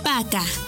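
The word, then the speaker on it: пока: P-A-Y-A-L-U-S-T-A. Payalusta. ¡Muy bien! Para finalizar, la palabra adiós se dice Paca. Paca. Y se escribe Paca. P. A. K. A. Paca. Repite conmigo. Paca. ¡Muy bien!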